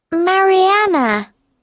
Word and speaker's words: Mariana: Mariana. – How do you spell that? – M-A-R-I-A-N-A.